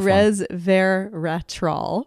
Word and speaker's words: resveratrol: Because it's the red skins in the red wine, the grape skins, that give us the resveratrol. Did I say that right? – That's pretty close. That's a tough resveratrol,